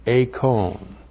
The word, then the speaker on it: eikon: So, we have, and then we have image, eikon. But eikon, not something I can just give to you in a few seconds. E-I-K long O-N. Eikon.